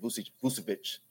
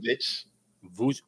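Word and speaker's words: Vučević: They have the ability to throw the ball in the post with Vucevic. – There you go. Finally, you said his name correctly. I'm proud of Vucevic.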